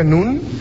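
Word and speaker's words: Nun (נון): La N, o sea, no es que aparezca el nombre Nun, sino la letra N que se pronuncia Nun, la G, la H y la S.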